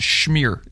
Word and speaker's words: schmear: schmear on that bagel too, and then that's pronounced schmear. – Yeah. Schmear.